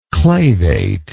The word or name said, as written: clavately